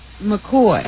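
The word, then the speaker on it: McCaughey: You know our Lieutenant Governor, Betsy McCauley? – McCoy. – Whatever. Yeah. No one can say her name because it's spelled McCaffey.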